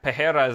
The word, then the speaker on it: Pereira: Yeah, Pejera is the proper pronunciation.